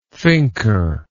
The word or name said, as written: thinker